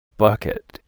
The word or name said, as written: bucket